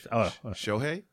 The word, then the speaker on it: Shohei: What is Shohei? – Yeah.